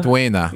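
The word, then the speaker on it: Maridueña: Meriduena.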